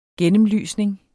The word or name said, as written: ISNET